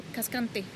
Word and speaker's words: Cascante: Cascante, y el segundo es Monajova.